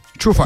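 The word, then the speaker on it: Coufal: Kofal mı okunuyor? – Çufal.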